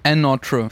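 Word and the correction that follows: Anotr: featuring 54 Ultra. – Ich glaube, Another wieder ausgesprochen.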